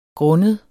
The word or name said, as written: grundet